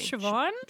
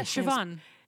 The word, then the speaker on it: Siobhan: Siobhan. – Sh- Siobhan, but it was with a B, so it was always like. Sh- yeah, Siobhan.